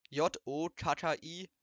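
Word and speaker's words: Gnocchi: die goldgelbenen Gnotten, gestampften Kartoffeln und Mehl werden eigentlich Njotokki ausgesprochen. Jotkki.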